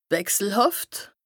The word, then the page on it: wechselhàft: Bas Rhin; Strasbourg